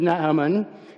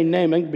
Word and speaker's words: Naaman: So this sermon is called Naaman, and the actual pronunciation of his name is Naaman, but we're going to say Naaman because we like to English everything. So it's Naaman.